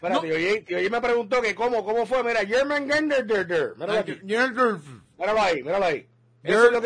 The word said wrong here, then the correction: Jormungandr: La J y la O con apóstrofe, your. – Se supone que sea your. Jormungandr. – Jormungandr.